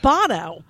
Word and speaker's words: Bono: When you two first came out, I had to remind myself, you don't say it like Sonny Bono. The guy's name is Bono.